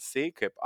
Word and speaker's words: Sekep: Da schreibt man mich einfach, äh, ja, mein Nachname Pekes rückwärts. Das hört sich dann Sekep an.